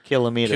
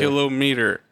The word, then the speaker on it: kilometer: But then you go up from there and people start changing the emphasis on the wrong syllable. – Ah, yes. – Millimeter, centimeter, kilometer. – Kilometer, not kilometer.